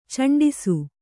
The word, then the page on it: ಚೆಂಡಿಸು: kannada; ceṇdisu